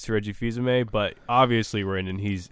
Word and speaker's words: Reggie Fils-Aimé: Reggie Field. I never know how to say his name. It's like Reggie Fils-Aimé. – Fils-Aimé. Okay.